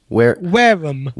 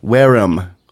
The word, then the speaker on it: Wareham: Actually, it was close to where I was brought up, and actually we pronounce it Wareham. – Not Wareham? – Not Wareham. No, good to know. Good to know how to pronounce my name. Say it again. – There you go. Wareham. – Wareham. Wear them out. Yeah. I wear them out. – That's right. It's exactly like that.